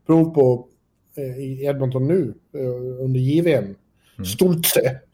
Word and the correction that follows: Stützle: Eller Stoltze, eller hur uttalar man det? Tim Stützle skulle jag säga. Stützle, ja förlåt.